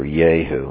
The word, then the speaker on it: Jehu: Jehu the son, I know that's how you say it in Hebrew, Jehu. Who's this Jehu? Maybe Jehu sounds better.